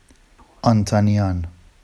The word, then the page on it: ɑntɑnijɑn: Vafsi